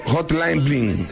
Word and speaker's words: Hotline Bling: Entre sus canciones más destacadas está One Dance, Good Plan and Hosting Billing. ¿Cómo se pronuncia eso? Hotline Bling.